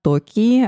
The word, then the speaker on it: Токи: Правильно же я говорю ударение? Правильно. Отлично кайф. Обычно гости у меня сами рассказывают о себе, поэтому расскажи чуть-чуть о себе. – В общем, всем привет! Я Токи, автор и ведущая подкаста «Япония на самом деле».